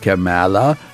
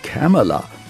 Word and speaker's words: Kamala: Nicht Kamala, sondern Kamala, und auch seine Falschaussagen zu Kamala Harris korrigierte.